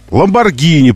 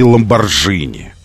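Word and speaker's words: Lamborghini: Ламборгини придумано, что мы прочитываем, а не Ламборжини.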